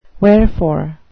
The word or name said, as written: wherefore